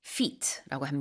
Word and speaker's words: feat: 업적, feat라고 합니다. 발음은 우리 발이랑 같지만 스펠링은 F-E-A-T니까요.